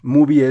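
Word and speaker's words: MUBI: Movie es eh, M-U-B de burro y latina, ¿no?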